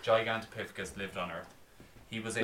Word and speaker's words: Gigantopithecus: Good on you pronouncing that. – Gigantopithecus. – Yeah, it's pretty good. – I said it a good few times. – Gigantopithecus. – Gigantopithecus. – Oh, there we go.